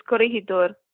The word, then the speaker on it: Corregidor: Oh, you said it fine first. It's Corregidor.